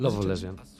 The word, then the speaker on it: Love of Lesbian: Love of Lesbian.